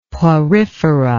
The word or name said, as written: Porifera